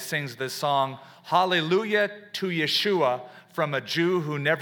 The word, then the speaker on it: Yeshua: And he was converted during the time of the 60s to what the Jewish believers call Yeshua, Jesus Christ, the Hebrew pronunciation Yeshua, the long-awaited Messiah of Israel.